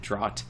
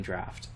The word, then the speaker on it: draught: my guess is that either younger children or their parents reading to them are gonna pronounce it draught, and not end up with the humor that is deflating draft.